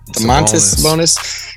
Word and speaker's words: Domantas Sabonis: Did I say that right? Domantas Sabonis? – Yeah, Domantas. I mean, I remember his pops, Arvidas.